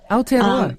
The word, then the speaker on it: Aotearoa: Because I don't know what it's like in New Zealand, in Aotearoa, but I'm sorry, my pronunciation. I've been practicing. – That's good. Aotearoa. Yes, good.